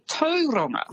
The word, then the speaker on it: Tauranga: And Tauranga as well is actually Tauranga.